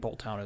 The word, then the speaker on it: Boltund: my new favorite Pokemon is Boltund. – Yeah. – Boltund, or however you say his name. – I think Boltund is right.